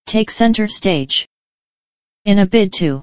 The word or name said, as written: take center stage